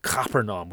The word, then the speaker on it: Capernaum: If you're in Israel, the Jews pronounce it Capernaum with a very hard C.